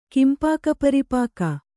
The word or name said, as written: ಕಿಂಪಾಕಪರಿಪಾಕ